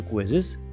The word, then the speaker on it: Quizizz: And the interesting thing is that its name is also quizzes, but the spelling is slightly different. It is spelled as Q-U-I-Z-I-Z-Z.